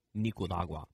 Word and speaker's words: Nicaragua: Nick Wallenda last night crossed Nicaragua. I can never pronounce that. I need your help. – Nicaragua.